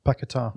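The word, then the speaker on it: Paquetá: Paqueta.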